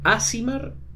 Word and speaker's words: Aasimar: Un Asimar eh, nace no, no, como... No, Asimar. – Asimar, perdón, todavía no, no tengo bien la pronunciación un Asimar nace como la contrapartida de los Tiflines.